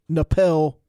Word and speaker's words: Napel: It's Aaron Napel, rhymes with Bell.